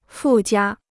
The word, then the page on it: 附加: fù jiā